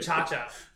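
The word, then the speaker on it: ChaCha: I thought it was saying her name right. – Cha-cha. – You said Serena Chachki.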